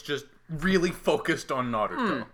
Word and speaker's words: Naruto: Also, did you just do the pronunciation like you did? – Naruto? What? It's right! – I'm just glad you flipped the R, that's all.